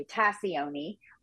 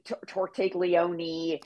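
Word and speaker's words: Tascioni: It's actually Tassioni. We do have a running joke. Many characters come on the show and don't know how to pronounce her last name. So you are in good company there. Okay. And we make a whole bit of it, actually, which is hilarious. Some people, you know, call it like Elsbeth Tortiglione, Tascione, Tacchione. It's sort of our running joke.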